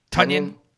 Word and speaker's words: Tonyan: And they've got some good, throw-in work from Tonyan, right? That's how you pronounce his last name, the tight end. – Tonyan.